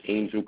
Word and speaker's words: Angel: My name is Angel Camacho.